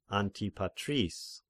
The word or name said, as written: Antipatris